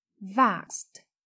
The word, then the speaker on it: vaxxed: Lưu ý động từ vax khi thêm ed thì chúng ta phát âm là vaxed với âm t ở cuối vaxed.